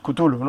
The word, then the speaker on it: Cthulhu: No ha comentado que está haciendo un cómic contigo para la revista Cthulhu, que nunca he sabido pronunciar Cthulhu, ¿no? – Chulo. Cthulhu, sí.